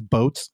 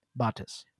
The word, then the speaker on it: Botes: I'm like, do you think that's boats? And she's like, it's definitely not boats. – How do you spell it? – B-O-T-E-S. – Botes. – Botes, yeah. So Costa Botes.